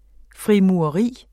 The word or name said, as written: frimureri